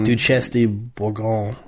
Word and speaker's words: Duchesse de Bourgogne: Duchesse de Bourgogne.